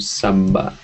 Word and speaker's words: sabba: Hence the name Sabba. Hence the Sabba in the name. Sabba means all the long A here, this is sabba, but that's because it's mixed with the word asawa.